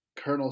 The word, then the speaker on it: colonel: It's also wild we pronounce that word colonel. – Yeah. – There's no R in it at all.